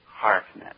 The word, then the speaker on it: Heartnet: Heartnet. I believe I'm saying that right. – So yes, you are.